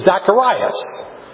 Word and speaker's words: Zacharias: Well, the problem is, the way to say it in Greek, which is what it's written, Luke is written in, is Zacharias.